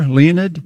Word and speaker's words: Leonid: I think this band called Leonid and Friends. – Yeah, I'd never heard of them till you brought them up. – Is it like the meteor shower, Leonid? – Yeah, it is.